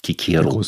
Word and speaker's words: Cicero: Also Cicero, wie eigentlich ausgesprochen wird, ne?